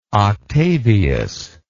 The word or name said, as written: octavius